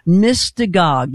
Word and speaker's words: Mystagogue: Mystagog.